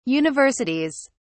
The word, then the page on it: universities: British English